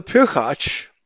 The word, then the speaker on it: pirchach: It's P-I-R-C-H-A-C-H, pirchach, meaning progeny, brood, children, or descendants.